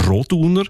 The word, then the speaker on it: Roduner: Also üblicherweise betonen wir ja Namen in der Deutschschweiz, wo drei Silben haben, immer auf der ersten Silbe. Also Andereck, Zollinger, Bertschinger, Ochsenbei. In dem Fall ist es aber eher die zweite Silbe, die betont Roduner.